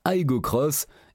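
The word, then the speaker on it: Aygo X: Elle s'appelle désormais Aigo X, que l'on prononce Aigo Cross, et repose sur une plateforme 100% maison, baptisée TNGAB, qu'utilisent également les Yaris et Yaris Cross.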